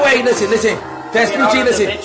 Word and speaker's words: Vespucci: Vespucci, right? – Vespucci, yeah.